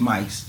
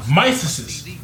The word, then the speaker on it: mice: Yeah, it's not mysises, bro. It's just mice, just so you know. – Nah, nigga, it was more than one. Mysises.